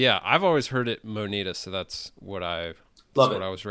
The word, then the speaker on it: Moneta: Moneta. – Moneta. How do you spell that? – M-O-N-E-T-A. – Oh, Moneta with an E. I would have gone like M-O-N-I-D-A, Moneta.